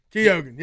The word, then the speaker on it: Keoghan: Tell me how to say the guy's name again. Barry Keoghan? – Keoghan. Yeah, it's just like.